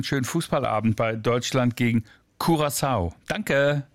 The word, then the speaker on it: Curaçao: Also richtige Aussprache, Curaçao. – Wenn man es wie die Inselbewohner machen möchte, genau. Curaçao wäre die niederländische Variante, habe ich aber auch oft dort gehört, geht beides.